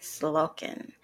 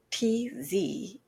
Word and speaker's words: Tzolk'in: I believe I'm saying it right, Tzolk'in. It's spelled TZOLK'IN, Tzolk'in.